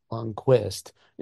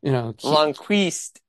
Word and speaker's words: Lundqvist: I'm believe if I if I be, if I believe this right, I believe I hate trying to pronounce his name, Lundqvist. – Yes. – Because it's not Lundqvist. – Lundqvist.